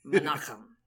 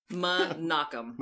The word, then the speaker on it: Menachem: Is it Mikhaim? Is that how we're pronouncing it? – Mikhaim Goldens? – We're not pronouncing it in any way. It's Menachem. Menachem.